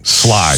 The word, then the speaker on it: Schley: Am I doing that right? – Sly. – Sly. Of course I'm not doing it right. Sly County. Again, there's a million counties in Georgia. Mm-hmm. Not everybody knows how to say it. – That's one that Jeff and I got corrected on.